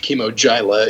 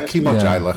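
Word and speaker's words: Kimogila: I think it's pronounced Chemo Gyla. Is that not what we settled on? – Yeah, Chemo Gyla. – Chemo Dia? – Yeah, I thought it was I thought there was a D in there somewhere.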